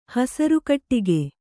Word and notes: ಹಸರುಕಟ್ಟಿಗೆ: Kannada

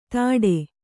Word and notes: ಧಡೆ: kannada

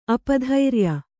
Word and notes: ಅಪಧೈರ್ಯ: apadhairya

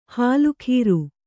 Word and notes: ಹಾಲುಖೀರು: Kannada

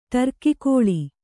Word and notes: ಟರ್ಕಿಕೋಳಿ: ṭarki kōḷi

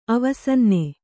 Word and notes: ಅವಸನ್ನೆ: avasanne

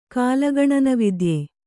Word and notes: ಕಾಲಗಣನವಿದ್ಯೆ: kālagaṇana vidye

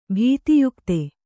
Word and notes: ಭೀತಿಯುಕ್ತ: kannada

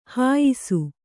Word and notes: ಹುಯ್ಸು: huysu

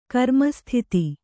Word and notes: ಕರ್ಮಸ್ಥಿತಿ: Kannada

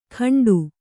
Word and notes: ಕಂಡು: Kannada

